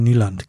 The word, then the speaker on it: Nyland: Also damals, als wir so den Björn Nyland, genau. Oder Christoph, wie spricht man das aus, wenn NY-Land auf Norwegisch, vermutlich? – Ja, das Problematische ist das Y, der Y-Laut, der irgendwo zwischen I und Ü ist.